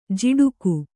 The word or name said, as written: ಝಾಡಿಕೆ